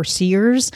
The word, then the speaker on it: CIRS: Dr. Judy herself kept pronouncing it CIRS or CIRS. So I don't know. Do you have any advice on that? – I would say the majority of people say CIRS.